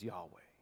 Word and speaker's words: Yahweh: And that is identified in the Hebrew tetragrammaton, the four letters of Yod, He, Vav, He, which is sometimes pronounced as Yahweh.